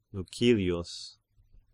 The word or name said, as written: Lucilius